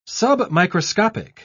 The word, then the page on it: submicroscopic: British English